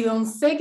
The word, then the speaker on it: Donze: Ya, mi Instagram es colach, arroba, naquera arroba, Donce y, y Donce, que en mi apellido, se escribe D de dedo, O-N-Z-E, Cata Donce Colach.